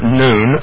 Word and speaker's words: Nun: And it looks like Nun, but we actually, it's pronounced Noon. So Brother Joe, if you will, we'll go ahead and switch it over to Noon.